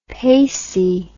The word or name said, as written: pacey